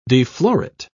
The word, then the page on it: deflorate: British English